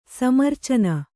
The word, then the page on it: ಸಮ್ಮೂರ್ಛನ: sammūrchana